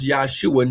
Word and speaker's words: Yahshua: Listen, because Yahshua HaMashiach, yes, Yahshua, his real name would have been pronounced Yahshua, not Jesus, because there's no J in the Hebrew language.